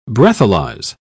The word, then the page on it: breathalyze: British English